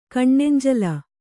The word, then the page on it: ಕಣ್ಣೆಂಜಲು: kaṇṇenjalu